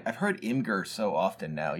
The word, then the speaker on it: Imgur: Imgur? Is that how you pronounce that? – I know. Isn't that ridiculous? – Is that really how it's pronounced? – It is how it's pronounced.